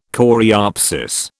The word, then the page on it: coreopsis: British English